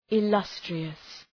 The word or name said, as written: illustrious